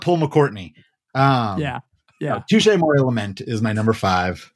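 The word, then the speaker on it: Touché Amoré: My number five is a little band named, I think it's pronounced Touch-A-More, I think. – Touch-A-More. – That's it.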